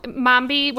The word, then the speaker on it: Mambe: Okay, so Mombi, what we're talking about, it's spelled M-A-M-B-E.